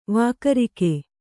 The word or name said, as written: ವಾಗುರಿಕ